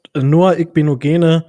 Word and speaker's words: Noah Igbinoghene: Okay, du hast Noah Igbinoghene jetzt schon vor allem auch sehr gut ausgesprochen.